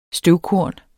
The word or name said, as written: støvkorn